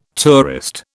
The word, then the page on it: tourist: British English